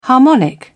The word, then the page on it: harmonic: British English